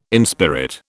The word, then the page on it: inspirit: British English